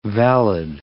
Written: valid

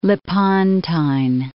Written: Lepontine Alps

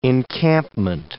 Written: encampment

